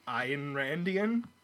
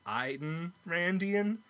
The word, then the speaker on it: Ayn Randian: It's a little Ayn Randian, 2 syllables, Ayn Randian.